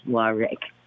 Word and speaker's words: Warrick: And they're absolutely right because my name is Warwick, but it's spelled W-A-R-R-I-C-K.